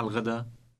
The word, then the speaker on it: الغدا: Remember the L sound on the beginning of the word for lunch, which stands for the definite article. – أي ساعة الغدا؟